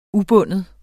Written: ubunden

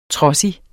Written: trodsig

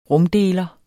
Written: rumdeler

